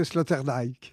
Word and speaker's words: Sloterdijk: Vous venez de faire paraître donc à quatre donc un ouvrage collectif, Pierre Dauques, Francis Fukuyama, Marc Guillaume et Peter Sloterdijk, je prononce bien ? – Non, il vaut mieux prononcer Sloterdijk.